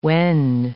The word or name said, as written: wend